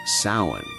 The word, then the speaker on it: Samhain: Their most significant festival was Samhain, pronounced Samhain, marking the final farewell to summer and the welcoming of the desolate dark winter, a season deeply intertwined with death.